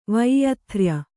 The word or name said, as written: ವೈಯಥ್ರ್ಯ